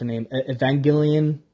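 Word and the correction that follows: Evangeline Lilly: Evangelion, holy shit. Eva Lily. Evangeline Lily. Jesus, I'm so bad at pronouncing names.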